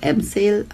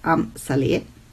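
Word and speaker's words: Amsale: Manche sagen auch Amsel, aber ich glaube, die korrekte Aussprache ist laut Wikipedia Amp Saleh.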